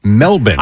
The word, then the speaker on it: Melbourne: You mean Melbourne? – It's pronounced Melbourne. For Melbourne? – The Melbourne. Yeah, there were locals.